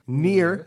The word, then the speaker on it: Nier: The game is Nier, pronounced Nier, spelled N-I-E-R. I actually Googled how to pronounce it, and they say Nier. It's almost like a French-German bastardization, and that will make more sense as we get into more of the game.